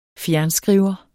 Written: fjernskriver

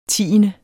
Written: tiende

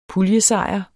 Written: puljesejr

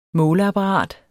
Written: måleapparat

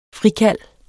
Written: frikald

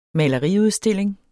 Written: maleriudstilling

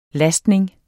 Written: lastning

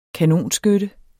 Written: kanonskytte

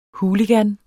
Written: hooligan

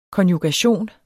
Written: konjugation

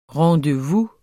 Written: rendezvous

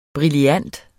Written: brillant

